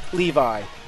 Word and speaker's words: Levi: And Sarah Ackerman says it is pronounced like the gene, so it is Levi.